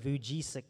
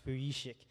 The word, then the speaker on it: Vujicic: I sometimes pronounce it Vujicic, but actually, it's not pronounced that. It's Vujicic.